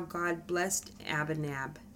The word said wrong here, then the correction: Abinadab: Abinadab? – Abinadab.